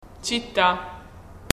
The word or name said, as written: città